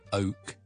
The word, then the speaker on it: oak: 或者美式英文 O O O 咁啦，英式英文 O O O O 完之后先加个 K，跟住将成个 syllable 缩短，好快咁读完 oak oak oak。